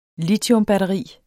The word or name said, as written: litiumbatteri